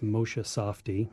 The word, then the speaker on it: Moshe Safdie: But yeah, on Monday, the city of Boise released these renderings, these artist renderings from world-renowned architect Moshe Safdie. I hope I'm pronouncing that right. – I think you are.